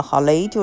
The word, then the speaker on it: 郝蕾: 其实这个电影我还喜欢的一个原因，是因为郝蕾，郝蕾啊。应该你发音叫雷啊，郝雷就是他呢，